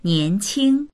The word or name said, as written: nian2qing1